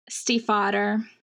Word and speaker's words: Stiefvater: Um, speaking of lyrical prose, Maggie, I don't know how to say her last name, Stiefvater. – Steve Fader.